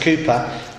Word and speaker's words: Cowper: William Cowper, spelt Cowper but pronounced Cooper, the 17th century poet who wrote many hymns with John Bunyan.